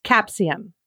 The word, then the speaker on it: capsaicin: How do you say it? – It's capsaicin.